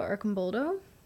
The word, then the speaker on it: Arcimboldo: Arcimboldo.